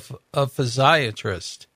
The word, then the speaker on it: physiatrist: What is a physiatrist? – Yeah, so actually you did pronounce that correctly. So most people actually mistake it for being a psychiatrist.